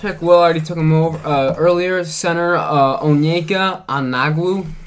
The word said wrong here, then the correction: Okongwu: Did I butcher that, Will? – Okongwu.